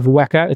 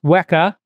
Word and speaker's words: Weka: So Weka is pronounced as any Greek unit. So you probably know how to pronounce mega, tera, exa. So you're just doing a weka.